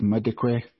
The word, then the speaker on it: Madikwe: even though it's spelt with an L, it is Madikwe, Kristen Payton, Celia Serrane, Nomnikelo Nikki Vito, Nicole Walraven, and Ontatile Zulu.